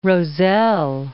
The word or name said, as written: Roselle